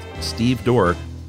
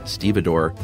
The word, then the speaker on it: stevedore: Then Josh pontificated about how it's pronounced exactly like it's spelled, stevedore. But I got news for you guys. It's most assuredly stevedore.